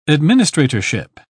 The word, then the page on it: administratorship: British English